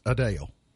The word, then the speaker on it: Adele: She told us herself that Adele is pronounced incorrectly. That it's Adele.